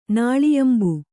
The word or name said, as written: ನಾಳಿಯಂಬು